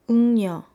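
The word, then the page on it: 웅녀: eungnyeo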